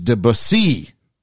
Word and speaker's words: Debussy: It's Debussy. At least it's best I can do from the phonetic spelling he's given me. Debussy.